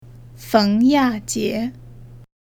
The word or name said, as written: feng ya jie